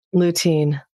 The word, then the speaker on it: lutein: It's spelled L-U-T-E-I-N. – Lutein. – Lutein, yes. I always mispronounce that.